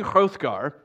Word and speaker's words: Hrothgar: King Hrothgar, and you have to spit when you say his name,